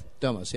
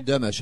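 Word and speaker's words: Dömös: Domos, yes. It's actually Domos in Hungarian, but you don't have umlauts in the English language.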